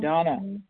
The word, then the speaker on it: Dana: Good morning, Donna. – Thank you for the warm welcome. This is Donna B., recovered compulsive overeater and bulimic, calling from Denver. I do spell my name D-A-N-A, although it's pronounced Donna.